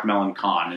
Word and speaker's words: Melancon: Mark Melanson, Mark Melancon is how his name should be pronounced, went to the Giants, apparently.